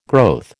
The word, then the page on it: growth: American English